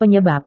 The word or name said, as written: penyebab